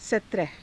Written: satraha

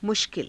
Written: mushkila

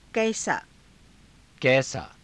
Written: kaisaa

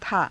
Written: thaa

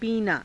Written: piinaa

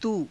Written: tuu

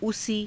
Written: usii

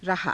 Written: rahaa

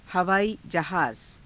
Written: havaaii_jahaaza

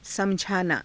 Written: samajhaanaa

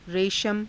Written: reshama

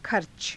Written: kharca

